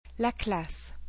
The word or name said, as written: La classe